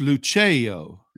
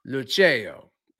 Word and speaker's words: Lucio: Remember, it's Lucio. – Lucio. – Yeah, you got to do it with your hand. The hand helps you sound out the syllables in it. – Thank you. Right. I'm so uncultured. Thanks to you. – Well, it's not culture. It's just an Italian thing.